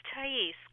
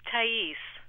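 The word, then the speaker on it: Thaïs: Hi, this is Thais calling from San Francisco. – Whoa. – What? Say it again. – My name is Thais. – How do you spell it? – T-A-I-S. – T-H-A-I-S with two little dots over the I.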